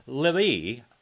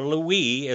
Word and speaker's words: Levi: And out of the tribe of Levi, or Lewi is how it is pronounced in Hebrew, Lewi.